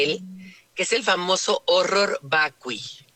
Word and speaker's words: horror vacui: Horror vacui. – Te voy a decir que horror vacui. El horror vacui, o sea, pero se pronuncia horror porque está en latín.